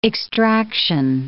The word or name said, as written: extraction